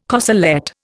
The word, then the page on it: corselette: Indian